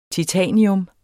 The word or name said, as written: titanium